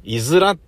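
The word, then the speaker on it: 五浦: いずらっていうのは、漢数字の 5 に浦島太郎の裏でいずらと読むんですが、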